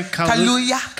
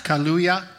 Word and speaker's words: Kaluuya: Kaluuya. – Kaluuya.